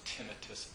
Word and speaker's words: tinnitus: So out of the blue, I had become one of apparently 20 million people in the United States who suffer from a condition called tinnitus, also pronounced tinnitus apparently. But I think people are more familiar with tinnitus as the pronunciation.